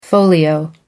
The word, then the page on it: folio: American English